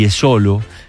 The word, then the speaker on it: Jesolo: Esto fue ayer de tarde con más de 90% de humedad en Jesolo, y Jesolo, se pronuncia así, nos dice, cerca de Venecia.